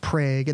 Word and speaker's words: Prague: So there's a town in Oklahoma that's spelled Prague, like the country, but it's pronounced Prague in the same way that Miami, Oklahoma is pronounced Miami, like officially.